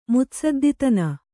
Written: ಮುತ್ಸದ್ದಿತನ

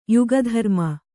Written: ಯುಗಧರ್ಮ

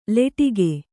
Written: ಲಟಿಕಿ

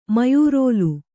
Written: ಮಯೂರೋಲು

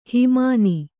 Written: ಹಿಮಾನಿ

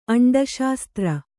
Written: ಅಂಡಶಾಸ್ತ್ರ

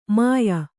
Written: ಮುಯ್ಯೀ

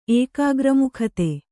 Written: ಏಕಾಗ್ರಮುಖತೆ

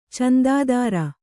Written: ಚಂದಾದಾರ

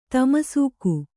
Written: ತಾಮಸಿಕ

